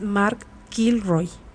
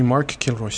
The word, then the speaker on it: Kilroy: Al mismo tiempo, la policía del Estado buscaba ya compresión al estudiante narco, más bien, no narco, norteamericano, es Mark Kilroy. Así se pronuncia. – Sí, Mark Kilroy, sí